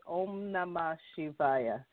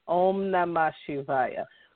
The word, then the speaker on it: Om Namah Shivaya: And the way you pronounce it is, and the way it goes is Om Namah Shivaya. Om Namah Shivaya.